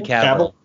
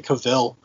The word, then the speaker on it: Cavill: Henry Cavill. – Cavill. I always want to say Cavill.